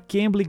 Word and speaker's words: Cambly: o Vince porque a Cambly